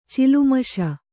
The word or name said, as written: ಚಿಲ್ಮಿಷ